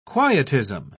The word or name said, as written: quietism